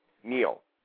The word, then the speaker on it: Neal: N-E-A-L. – N-E-A-L. That's how you spell it. How do you say it? – Neil. – And what about Schaefer? – S-C-H-A-F-F-E-R. Schaefer. – How do you say it? How do you say it? – Schaefer. Schaefer. Neil Schaefer.